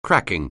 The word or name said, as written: cracking